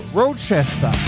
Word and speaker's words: Rochester: Rochester.